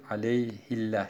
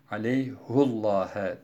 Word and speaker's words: عَلَيْهُ اللّٰهَ: Normalde aleyhi Kur'an-ı Kerim'in tamamında bu kelime aleyhi şeklinde yazılırken, okunurken bu Fetih Suresi'nin 10. ayetinde وَمَنْ اَوْفَى بِمَا عَاهَدَ عَلَيْهُ اللّٰهَ فَسَيُؤْتِيهِ اَجْرًا عَظِيمًا ayetinde aleyhi şeklinde değil, aleyhillah şeklinde değil, aleyhullah'a diye okunmuştur. Yine Hafs'a göre bir başka farklı okuyuş Furkan suresinin 69.